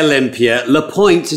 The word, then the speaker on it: Olympia LePoint: It's Lapointe in English. In French, it's Lapointe. But I go by Olympia Lapointe.